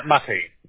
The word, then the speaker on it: Matej: Yes, my name is M-A-T-E-J. Is his name? – No, yeah, it is spelled correctly. I just want to know am I pronouncing that right? – Mate is my name, Mate.